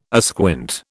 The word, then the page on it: asquint: British English